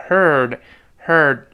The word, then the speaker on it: heard: Heard 就是刚才 hear 一般过去式，在 hear 后面加一个字母 d，注意不要读 hear 的，要读成 heard heard that 就刚才那个事儿。